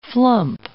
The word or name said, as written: flump